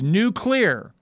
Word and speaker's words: nuclear: I have a card that I keep with my notes, and it says new clear and bold. It's like new is underlined and clear is underlined. So I pronounce it with two syllables.